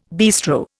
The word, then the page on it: bistro: Indian